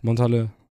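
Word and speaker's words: Montalchez: Montager les Prises. – Boah, habe ich gut ausgesprochen. – Also es wird gesprochen Montal und dann CHEZ. – Montale.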